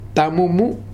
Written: Tamumu

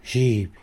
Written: zhiby

